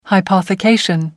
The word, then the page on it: hypothecation: American English